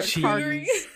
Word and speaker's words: charcuterie: Charcuterie? – Yeah.